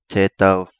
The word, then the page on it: Tetau: Kapingamarangi